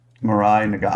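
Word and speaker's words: Mirai Nagasu: Pat just said it. It's Mirai Nagusu. – Mirai Nagusu.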